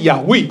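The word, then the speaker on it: 雅 威 (יהוה): 何西阿呢是耶和华，耶和华他发音是雅威，对不对哈？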